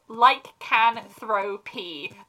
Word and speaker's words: lycanthropy: I think it's for an American accent, so it's lycanthropy, as in throw, like, pee, as in the, you know, urine.